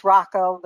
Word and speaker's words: Rocco: Rocco, I can pronounce, or what's the right name? – You can say it either way. It's Rocco, but that's fine.